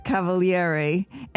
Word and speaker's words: Cavalieri: I'm going to have a really good time in the next few minutes talking with Felix Cavalieri. And, you know, I want to ask you, Felix, the Italian pronunciation would be Cavalieri, right? – Absolutely.